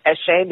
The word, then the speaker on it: Eche: And it's actually a word that originally I don't know what the pronunciation is supposed to be, so I just decided to pronounce it Eche because I think it sounds very chic and upmarket. And since nobody knows how it's supposed to be pronounced, then I can define it myself.